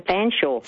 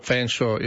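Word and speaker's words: Featherstonhaugh: In another life, I worked with a gentleman called Featherston, and he pronounced his name Fanshawe. – Fanshawe, yeah. It's actually, I think it's Featherston Shaw, and they chop out the Featherston bit, and it just becomes Fanshawe, doesn't it? – Mm.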